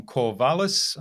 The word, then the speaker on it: Corvallis: Hi, I'm your host from Innova.Buzz, and I'm really excited to welcome to the Innova.Buzz podcast today from Corvallis, I think that's correct pronunciation, yes.